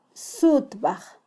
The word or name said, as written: sutbaj